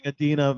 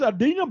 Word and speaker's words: Idina: Announcing Idina Menzel. No, he's not in it, but... Remember he got he butchered her name at the Oscars. Yes. – I'd like to introduce Adina.